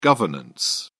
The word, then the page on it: governance: British English